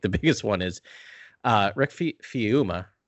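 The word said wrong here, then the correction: Famuyiwa: Famu Yiwa.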